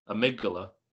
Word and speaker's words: Amygdala: How do you pronounce his name? – When you it say it out loud. Amygdala. – Amygdala? Okay.